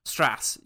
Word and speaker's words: Strauss: where Strauss says, Strauss, or whatever the other pronunciation is, says, oh, no, he corrects him. He says it's actually, you know, Strasser. Strass.